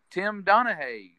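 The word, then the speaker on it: Donaghy: Tim Donahue, did I pronounce that correctly? – Yeah.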